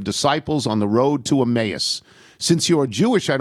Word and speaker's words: Emmaus: It's not Emmaus, but Emmaus, E-M-A-Y-U-S, Emmaus.